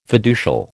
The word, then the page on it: fiducial: American English